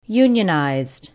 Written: Unionized

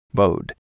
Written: Bowed

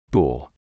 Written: Boule

